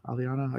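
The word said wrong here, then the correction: Allrianne: I can't pronounce the names. Ariane, thank you, Set.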